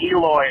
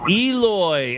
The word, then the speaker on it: Eloy: By the way, it's pronounced Eloy, Arizona. – Eloy, Arizona. Thank you.